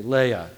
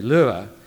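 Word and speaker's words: Löhe: In the Midwest, for whatever reason, they say Leia. Good Germans would say Lua. But I'll probably show now that I've been thoroughly contextualized and use the non-German pronunciation of Leia.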